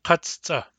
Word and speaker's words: katsuta: Katsuta. Katsuta. Three. We talked earlier about how katsuta has two C's. Both C's are pronounced.